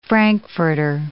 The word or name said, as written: frankfurter